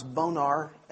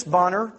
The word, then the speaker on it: Bonar: Again, a name that we pronounce sometimes Bonar. And in my reading, I found that Charles Whitfield spelled his name often in correspondence. It says phonetically, B-O-N-N-E-R. So maybe his name was really pronounced Bonner.